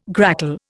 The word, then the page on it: grackle: Indian